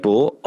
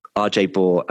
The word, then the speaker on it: Baugh: So it's at RJ Bohr on Twitter. – Let's just spell that surname out for them. – Sorry, yes. It's at RJ Bohr, B-A-U-G-H, Bravo Apple Umbrella Golf Hotel. So that's RJ Bohr on Twitter.